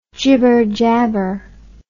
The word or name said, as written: jibber jabber